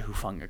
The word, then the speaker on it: Hufanga: Yeah, and they got Hufanga before I did. – And it's beyond me. I'm amazed I said Hufanga correctly that time.